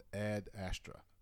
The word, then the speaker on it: Ad Astra: And Trevor said he recently saw a movie called Ad Astra.